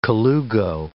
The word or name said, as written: colugo